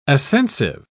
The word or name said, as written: ascensive